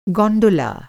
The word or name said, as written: गॉनडोला